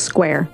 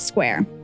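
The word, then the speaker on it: Squier: And I am going to probably mispronounce his last name quite a bit because it is spelled the same as Squire. But it is not pronounced that way. – It's just Square. – It's Square.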